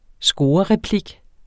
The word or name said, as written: scorereplik